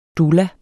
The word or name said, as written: DOULA